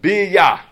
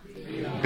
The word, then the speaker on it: Bia: Okay, and I'll tell you how to pronounce it first, then you'll say it with me. Bia. Can we say that? – Bia. – Bia. It's an acronym for Bria, Yetzira, Asiya.